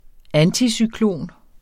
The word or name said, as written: anticyklon